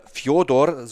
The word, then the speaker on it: Fjodor: Seit über 20 Jahren Seit über zwei Jahrzehnten liefert Fedor oder Fjodor, so wie er ja eigentlich ausgesprochen wird, denkwürdige Leistungen ab.